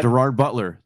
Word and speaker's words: Gerard Butler: I'd go Jared Butler. Am I pronouncing that right? – Gerard Butler. Yeah. Gerard, I think that's a, I think Jared Butler is the Wish version. So let's stick with Gerard.